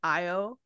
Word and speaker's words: Io: It's literally Io. Like, I-O.